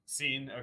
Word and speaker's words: Cine: Is that how you say it, Cine? – Cine, okay.